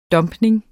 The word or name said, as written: dumpning